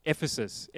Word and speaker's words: Ephesus: There in that area, Ephesus is how you say it.